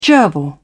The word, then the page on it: gerbil: British English